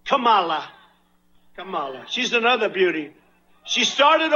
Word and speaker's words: Kamala: Now, she wants you to pronounce her name Kamala. Accent on the first syllable.